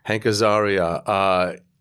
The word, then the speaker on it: Hank Azaria: Hank Azaria.